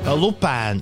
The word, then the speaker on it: Lupēna: Lupēna, Lupēna droši vien jārunā, jāizrunā jā, latviešu precīzi, bet Lupina raksta, jā.